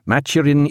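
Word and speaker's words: Mathurin: Maturin Idomanova. Maturin, spelt with an H, M-A-T-H-U-R-I-N, but pronounced without it, having ludicrous associations with idiocy in the jargon of that time.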